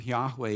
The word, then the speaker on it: Yahweh: And what scholars think today is this name, Yahweh, is really almost a breath. It's breathing in, yah, and breathing out, way.